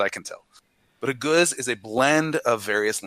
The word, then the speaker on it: gueuze: And so that is a guz, all right? It's a mouthful of a word. It's spelled variously G-E-U-Z-E or G-U-E-U-Z-E.